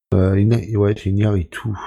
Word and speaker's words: Iñárritu: Alors, à la réalisation, alors excusez-moi pour la prononciation, on retrouve Alejandro González Iñárritu. Je sais pas si je prononce bien. – Iñárritu.